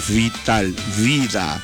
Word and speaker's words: vital: Ojo a la pronunciación, ¿ok? @vital. Porque dijera vital sería con la B de burro. Pero es la V que se pronuncia así: vital, vida.